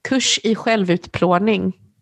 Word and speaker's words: Kurs i självutplåning: How do you say it in Swedish? – Yeah. Kurs i självutplåning.